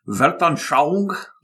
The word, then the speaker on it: Weltanschauung: Weltanschauung? – Yes, or worldview.